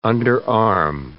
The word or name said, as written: underarm